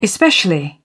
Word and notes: especially: British English

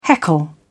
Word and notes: heckle: British English

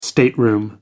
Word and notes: stateroom: American English

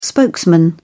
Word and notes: spokesman: British English

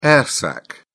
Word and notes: air sac: British English

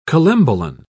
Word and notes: collembolan: British English

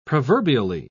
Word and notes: proverbially: British English

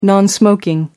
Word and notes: non-smoking: American English

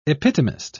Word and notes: epitomist: British English

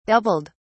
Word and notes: doubled: British English

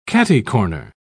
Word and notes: catty-corner: British English

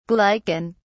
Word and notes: glycan: British English

